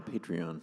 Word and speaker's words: Patreon: Like, I think the way people have patrons now, is that how you say it, or Patreon? – Patreon.